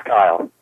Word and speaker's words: Cyle: How do you pronounce your name, C-Y-L-E? How do you pronounce it? – It's Kyle. – So that's K-Y-L-E. – Kyle with a C.